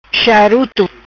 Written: Charuto

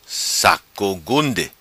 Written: Saco Gunde

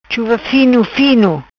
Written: Chuva Fino Fino

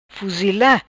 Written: Fuzila

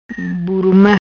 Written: Buruma

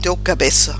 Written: Teu cabeca

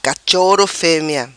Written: Cachoro Femea

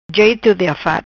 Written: Jeto di afat

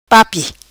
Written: Papi

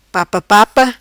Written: Papa papa

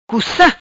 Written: Cuca

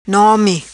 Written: Nomi

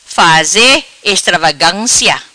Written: Faze Estravagancia

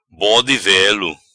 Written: Bode Velo

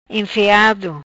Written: Enfeado

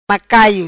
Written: Macaio

